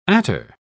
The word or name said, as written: attar